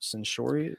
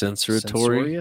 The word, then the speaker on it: censorious: So, yeah, Because YouTube is way too censorious. Is that the word? Censorious? How do you pronounce censoratory? – It? – Censoratory. Censoratory. Centaurian. – Censorious, right? – Sure.